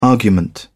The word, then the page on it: argument: British English